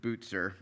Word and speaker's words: Bucer: Or Martin Bucer's, uh, uh Butzer is how you pronounce it.